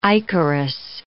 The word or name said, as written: ichorous